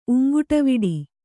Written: ಉಂಗುಟವಿಡಿ

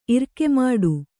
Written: ಇರ್ಕೆಮಾಡು

